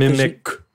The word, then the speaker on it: Mimiq: Mimic. – It's like a k. – Mimic. M-I-M-I-Q.